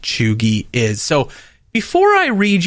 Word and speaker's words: cheugy: The word that I'm referring to is choogy. C-H-E-U-G-Y. Choogy. – Is that how it's pronounced? Choogy? – Correct.